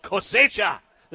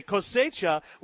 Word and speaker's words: Cosecha: They have this event called Cosecha. Am I saying that right? – Absolutely. – Okay, Cosecha, which is again October the 27th and 28th at the winery.